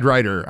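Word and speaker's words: Riter: I'm at Brad Ryder on Twitter, or X, if you call it that.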